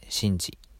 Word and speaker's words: 慎治: 慎むに収めると書いて、真治と読みます。